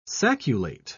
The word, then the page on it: sacculate: British English